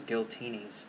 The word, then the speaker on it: Giltinis: I've heard that Giltinis rhymes with Martini or Martinis, so it's Giltinis.